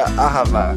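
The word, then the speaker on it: Ahava (אהבה): Nosso maestro, consultor de hebraico, está nos corrigindo, dizendo que a pronúncia correta seria Ahava.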